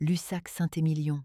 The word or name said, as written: Cabernet